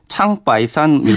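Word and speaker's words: 창바이산 (长白山): 중 중국어로는 창바이산 이렇게 발음하던데 이렇게 부르기도 하고요.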